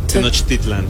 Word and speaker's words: Tenochtitlan: And Tenochtitlan. – Tenochtitlan.